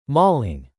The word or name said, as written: mauling